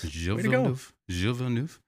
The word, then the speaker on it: Gilles Villeneuve: And today, we're going to talk about a man from Canada, a guy named Gilles Villeneuve. Did I pronounce that right? – Gilles Villeneuve, yes. – Gilles Villeneuve.